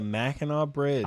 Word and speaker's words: Mackinac Bridge: It is the Mackinac Bridge. – I am the mighty Mac. – I'm friends Let's with a go. Michigander who taught me how to say that. Anyway, Mackinac Bridge.